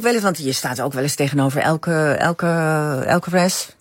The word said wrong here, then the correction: Alcaraz: Nou, zeg ik het goed? – Alcaraz, ja.